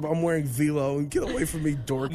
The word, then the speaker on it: Vlone: Vlone. Do you know what Vlone is, Lindsay? – What? – Vlone? – You mean V-Lone? – You spell it? – excuse me, it's a V-Lone? – Yeah.